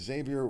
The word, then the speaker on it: Xavier: It's Xavier with an X. See, the X and the Z are right next to each other, and the X is pronounced Z, therefore spelled wrong on there. But on the graphic, it's correct. It's Xavier Twyman.